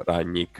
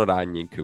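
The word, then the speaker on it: Rangnick: Alternative, eh, sempre sulla fascia destra, Diogo Dalot abbiamo sentito già un milione di volte, ora ra- tra l'altro non so come si pronunci onestamente, non ho mai capito Ragnic Nemmeno io. No, non lo so, eh, Inghilterra lo pronunciano Ragnic, quindi fa- farò lo stesso, anche se non è che siano proprio dei linguisti.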